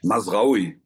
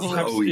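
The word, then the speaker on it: Mazraoui: Manuel Neuer, Lukas Hernandez, Nurser Masroi den ich irgendwann auch nochmal richtig aussprechen kann. – Masraui. Vielen Dank. Masraui. Vielen Dank. Masraoui.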